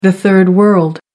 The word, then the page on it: third world: American English